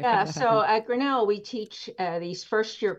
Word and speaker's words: Grinnell: And then how'd you end up at, am I saying that right? Grinnell College? – Grinnell College in Iowa.